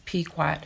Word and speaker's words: Pequot: Pequot Indians. And I really hope I said that right. It's Pequot, P-E-Q-U-O-T.